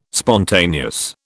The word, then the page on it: spontaneous: British English